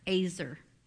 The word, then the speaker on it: ezer: That word helper is azer in the Bible. It's spelled E-Z-E-R.